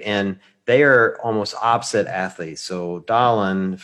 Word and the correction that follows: Dallin: I'm probably butchering his name. – Dallin, but you're close. You're close. Dallin. That's good.